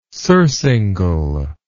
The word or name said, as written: surcingle